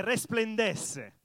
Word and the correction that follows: resplandece: Ho detto bene? Resplandes, non è più bello in spagnolo?